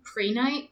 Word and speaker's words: Prehnite: How do you say the name of it again? Prehnite. Prehnite. Okay. It's spelled differently. It's spelled uh, P R E H N I T E.